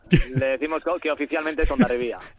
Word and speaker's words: Hondarribia: Hondarribia, Hondarribia. Naturalmente, la gente local lo pronunciamos así. – Vale. – Digamos, más hacia la comarca exterior, Hondarribi, pero bueno.